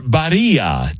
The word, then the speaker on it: Barilla: know that Barilla, or Barilla, depending on how you say it. – Ah, yes. – Barilla. Oh, I was once in Florence, so Barilla.